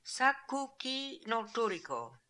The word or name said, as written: Sakookinoltooriko